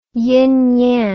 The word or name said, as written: yin yang